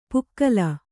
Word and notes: ಪುಕ್ಕಲ: pukkala

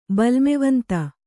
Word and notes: ಬಲ್ಮೆವಂತ: Kannada

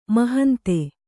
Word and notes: ಮಹಂತೆ: mahante

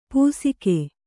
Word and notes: ಪೂಸಿಕೆ: pūsike

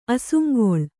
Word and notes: ಅಸುಂಗೊಳ್: Kannada

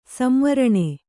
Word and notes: ಸಂವರಣ: samvaraṇa

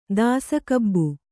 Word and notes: ದಾಸಕಬ್ಬು: kannada

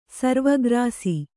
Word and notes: ಸರ್ವಗ್ರಾಸಿ: sarva grāsi; Kannada

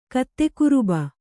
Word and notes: ಕತ್ತೆಗಿರುಬ: kannada

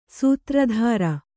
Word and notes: ಸೂತ್ರಧಾರಿ: Kannada

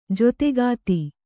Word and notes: ಜತೆಗಾತಿ: jategāti

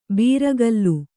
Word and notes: ಬೀರಗಲ್ಲು: bīragallu

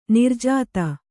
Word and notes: ನಿರ್ಜಾತ: Kannada; nirjāta

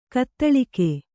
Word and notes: ಘಾತಾಳಿಕೆ: ghātāḷike

